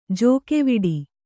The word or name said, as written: ಜೋಕೆವಿಡಿ